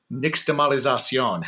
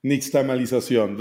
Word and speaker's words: Nixtamalización: Nixtamalización. How did I do? – Great, great. – Yes! Nailed it. – Nixtamalización. Very well said. Well, Nixtamal, that is from where the nixtamalización comes from corn become masa.